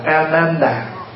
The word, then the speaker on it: A-nan-đà: Bình thường gọi tắt là A-nan, nhưng mà đúng cái tên của Ngài á, chúng ta phải đọc là A-nan-đà.